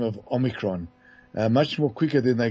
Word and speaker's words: Omicron: What's worrying about this particular variant, which is now called Omicron, that's the way it's actually pronounced, Omicron, its technical name was 11529.